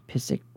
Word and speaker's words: Pszyk: The name of that road is North Pisic Road. That's spelled P-S-Z-Y-K.